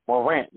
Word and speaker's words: Morant: Don't get me wrong, you know, John Morant, if I pronounce his name wrong, but I apologize. Morant, yep.